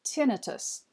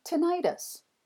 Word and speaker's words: tinnitus: tinnitus, while I insisted that it is pronounced tinnitus. Well, tomato or tomato, we are both right. You can say either, but hopefully you don't have to deal with the intrusive, annoying problem yourself.